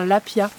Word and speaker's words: lapiaz: Comme le poisson. Je l'ai découvert tout à l'heure. Grâce à un pote à toi, qui connaissait le lapia, poisson. Alors ça s'écrit lapiaz. Et ça donne des points au Scrabble quand on ne sait pas où mettre le Z.